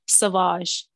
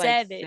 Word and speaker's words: Sauvage: Sauvage. I thought it was called Savage.